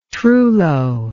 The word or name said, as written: trullo